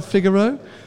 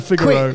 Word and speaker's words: Figaro: It's Figaro. – Nissan Figaro. Who's coming? The cappuccino coloured Nissan Figaro.